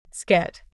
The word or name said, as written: Skit